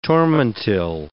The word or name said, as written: tormentil